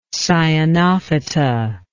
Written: cyanophyta

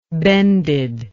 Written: bended

